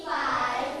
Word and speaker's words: fights: He fights fire. – Again please. – He fights fire. – มีเสียง S ไหม Fights.